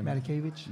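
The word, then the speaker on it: Matakevich: How do you pronounce his last name? – Matakavich. – Matakavich. Yeah. That's right.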